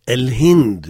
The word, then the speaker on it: El Hind: El Hind, il faut bien prononcer le ha.